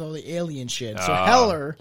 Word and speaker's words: Heller: Jerry Hellier. Heller. Fuck. – Yeah, I was like, you kept saying with a D, and it was bothering me. I was that's not his name. It's Heller.